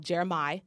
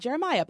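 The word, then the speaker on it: Jeremih: It's by a guy called Jeremiah. Not Jeremiah, but Jeremiah. And the song is called You and I, but it's spelled O-U-I.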